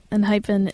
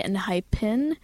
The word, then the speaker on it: ENHYPEN: The name ENHYPEN is like the name, is like the word hyphen, but with E-N in front of it. And I'm not fully sure if it's supposed to be ENHYPEN,